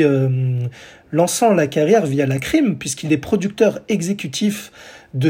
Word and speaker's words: La Crime: Et donc, euh, la crime, alors ça s'écrit L-A, comme l'article là, et crime en anglais, la crème, quoi.